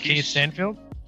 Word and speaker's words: Lakeith Stanfield: Um, like our boy, I, I was I, I don't want to fucking butcher his name, but was it Lakeith, Lakeith Stanfield? – Lakeith yeah, Stanfield.